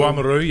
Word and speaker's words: Oamaru: I have to admit, I want to apologise to the people of Oamaru. Is that how you say it? Am I saying it in a room? Oamaru, yeah. It's pretty good.